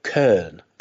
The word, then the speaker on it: Köln: Like you actually need to say, if you're going to try and say it correctly, you say Köln. So it's one of those weird, you know, umlautized vowels that is kind of hard to make if you're just speaking fluid English.